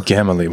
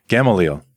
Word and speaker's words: Gamaliel: Gamaliel. – We couldn't figure out how to say his name. Gamaliel.